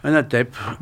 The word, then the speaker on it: ANATEP: Alors, j'ai dit ANATEP, ANATIP, tu le prononces comment? – ANATEP. – D'accord.